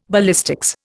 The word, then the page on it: ballistics: Indian